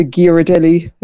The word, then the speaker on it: Ghirardelli: But whenever we came back, it was absolutely 100% compulsory to bring back a big bag of the Ghirardelli.